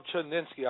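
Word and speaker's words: Chudzinski: Offensive coordinator Rob Chudnitsky, I think I pronounced his name right. – Yeah.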